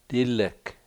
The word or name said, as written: dilek